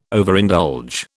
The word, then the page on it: Overindulge: British English